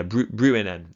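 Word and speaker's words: Bruinen: nearly capture him at the ford of Brunnen. Is that how you pronounce it? I think that's right. Yeah, Brunnen, that's right.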